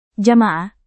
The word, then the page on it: jamaah: Indonesian